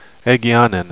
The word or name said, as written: egeanin